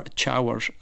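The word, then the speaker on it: Teamhair: Tara, or Chawr, as it's pronounced in Irish, means a sanctuary.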